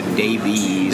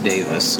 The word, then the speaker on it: Davies: Uh, and Davis, I should say Davis. All my English friends hate it when I say that. I'm like an American. I always D A V I E S. I always pronounce it Davies. Davies. Right, right, right, right. Just Davis. It's like as in okay.